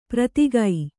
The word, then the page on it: ಪ್ರತಿಗೈ: pratigai